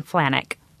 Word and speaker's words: Flanjnik: But I thought there was a J in there. – There is a J in there, oh. But it's Ben Flanjnik. – Oh, the J is silent.